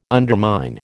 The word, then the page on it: undermine: American English